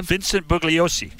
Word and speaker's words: Vincent Bugliosi: Vincent Bugliosi.